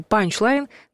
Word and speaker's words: панчлайн: Писать нужно слитно, а ударение ставить на первый слог. «Панчлайн». Хотя чаще используют короткий вариант «панч», где с ударением точно не ошибиться.